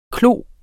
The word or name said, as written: klos